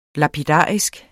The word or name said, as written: lapidarisk